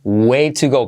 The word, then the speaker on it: way to go: Es una forma de decir buen trabajo, muy bien hecho, way to go. ¿Cómo se pronuncia esto? Fijaos, así, way to go. Way to se convierte T como R suave y esa U más bien como una O.